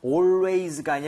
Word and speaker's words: always: Always가 아니라 al은 항상 얼에 가깝게 입 모양 벌리셔야 됩니다.